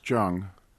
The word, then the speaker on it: Jung: It's Jung or Jung? – It's Jung.